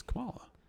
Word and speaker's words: Kamala: It's Kamala. Right? Am I saying her name right? – Yes, you are.